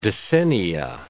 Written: decennia

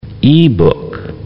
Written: e-book

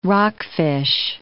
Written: rockfish

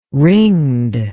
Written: ringed

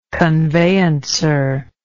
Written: conveyancer

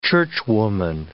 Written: churchwoman

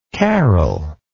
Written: carole

